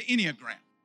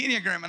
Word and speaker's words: Enneagram: And so one of the things that I was talking to him before I ever got there is I was sharing with him about the Enneagram. I said it right, didn't I? Okay. All right. The Enneagram.